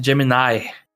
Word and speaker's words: Gemini: Os três jogos são Harvest Moon 64, 1080 Graus, 1080 Snowboarding e Jet Force Gemini, que a gente até discutiu no episódio passado, que essa é a forma correta de pronunciar, e eu falei Gem Gemini minha vida inteira.